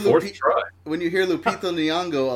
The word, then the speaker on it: Lupita Nyong'o: When you hear Lupita Nyong'o a lot, you eventually say it right.